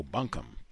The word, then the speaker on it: bunkum: After listening to last week's broadcast, it struck me that the word boomkung, referenced as a street in Edgefield, South Carolina, mentioned in the text, has a definition. Insincere speech." Oh, bunkum. Pronounced it incorrectly. Bunkum.